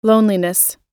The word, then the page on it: loneliness: American English